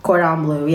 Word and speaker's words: cordon bleu: I'm probably pronouncing this wrong, but I had cordon bleu. – Yeah, cordon bleu, yeah.